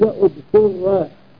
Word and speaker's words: اضطر: قال الله تعالى فمن اضطر، فمن اضطر إذا ابتدأ بالفعل قيل اضطر بضم الهمزة والطاء.